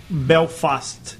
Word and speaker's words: Belfast: Belfast.